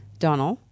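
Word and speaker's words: Donal: It's D-O-N-A-L, Donnell. Donnell. – Yes. – Donnell. I believe it's Donnell. – Uh-huh. – Logue. L-O-G-U-E.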